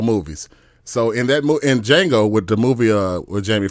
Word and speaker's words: Django: He was like, Django. He's like, you know, he was talking about the J is silent and all that.